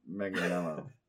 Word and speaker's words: Manganiello: Actor Joe Mangan... – It's Joe Manganiello. – Is that how you say it? – Yeah, I think it is.